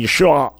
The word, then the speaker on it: Yeshua: It's actually no, it's actually Yeshua with an I in at the end that you'd be unable to pronounce because you didn't grow up with it.